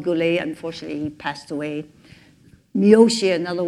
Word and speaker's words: Miyoshi: Miyoshi.